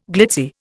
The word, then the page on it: glitzy: Indian